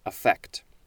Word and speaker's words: affect: The first form of affect is actually, I believe it is pronounced affect. So that is slightly different from what I was saying. Now we are reading the one that's affect, with the emphasis on the second syllable.